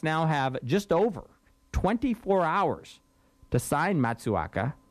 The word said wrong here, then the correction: Matsuzaka: I don't know if I have the pronunciation right, Matsuzaka, to a contract.